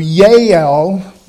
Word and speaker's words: Yael: In fact, in modern Hebrew, Yael, Y-A-E-L is how they pronounce it now.